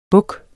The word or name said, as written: buks